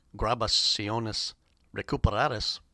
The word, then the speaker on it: Grabaciones Recuperadas: From an album entitled Grabaciones Recuperadas, which in Spanish is pronounced Grabaciones Recuperadas. That was Oscar Ellman with Tango Ritmo,